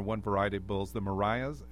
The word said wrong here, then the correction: Miuras: Am I not pronouncing it? – Miuras.